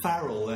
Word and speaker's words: Farrell: Am I saying that right? – I'd like to pronounce it Farrell, actually.